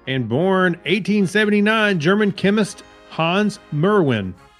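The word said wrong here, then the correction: Meerwein: Mirwein. – Oh, excuse me.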